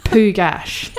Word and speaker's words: Pugach: It's not spelt Pugach. It's spelt P-U-G-A-C-H, but it's pronounced Pugach.